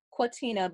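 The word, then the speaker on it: Kwateena: Quatena, but it's spelled, it's K-W-A-T-E-E-N-A.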